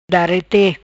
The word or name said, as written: Darete